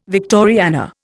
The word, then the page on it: Victoriana: Indian